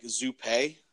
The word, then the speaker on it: Zuppe: I've probably heard any way you can pronounce my last name. I've heard over the years, all the way to where it sounds French, like Zuppe.